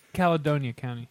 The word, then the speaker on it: Caledonia: All right, well, let's close out episode number 33 with, is it Caledonia County? How do you say it? – Calidonia County.